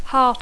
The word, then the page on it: heart: English